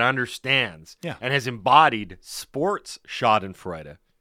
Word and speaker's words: Schadenfreude: I believe it's pronounced Schadenfreude. – What did I say? – Schadenfreude. – Yeah. Peter Schadenfreude? Yeah. Schadenfreude. – Schadenfreude. – I believe it's a German word.